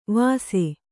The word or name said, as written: ವಸೆ